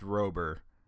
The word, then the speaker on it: Rober: It's probably pronounced Rober. Because it's R-O-B-E-R, not R-O-B-B-E-R.